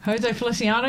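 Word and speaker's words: José Feliciano: It's from Jose Feliciano. I said that right. – Jose Feliciano, yep.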